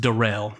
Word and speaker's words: Daryl: This is it's spelled Daryl, but it's pronounced Darrell. This is Darrell.